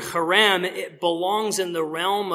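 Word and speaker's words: charem (חרם): What's actually just one Hebrew word, the verb that's used there in verse 17, it's a form of the Hebrew word charem. You know you're saying it right if you spit a little.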